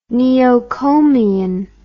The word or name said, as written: neocomian